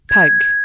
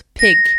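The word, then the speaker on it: pygg: P-Y-G-G. À l'époque, on le prononce « pug ». Et puis la langue évolue et la prononciation de ce mot avec. Au XVIIIe siècle, l'argile se prononce « pig », comme le cochon, P-I-G.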